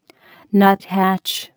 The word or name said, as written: nuthatch